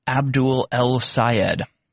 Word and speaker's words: Abdul El-Sayed: Michigan Senate candidate Abdul Al-Sayed says you cannot... If you can't pronounce his name, it's easy to pronounce. Abdul El-Sayed. Now, we get it.